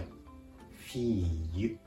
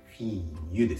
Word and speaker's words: fille: フィーユフィーユ